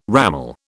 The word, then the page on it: ramal: British English